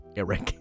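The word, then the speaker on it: Arryk: Eric and Auric, but they're both pronounced Eric.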